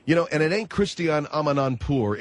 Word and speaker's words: Christiane Amanpour: I came on your program to praise Christiane Amanpour, which, by the way, that is her name, Christiane Amanpour. You people have mispronounced it.